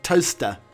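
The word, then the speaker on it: Towcester: Talchester is pronounced Toaster.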